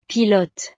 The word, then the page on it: pilote: frances